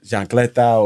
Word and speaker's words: chancleta: I was that kid with that chancleta or, you know, sandal in my pants, yeah. You know? Did I even say that right? Stuff. – Yeah, you did.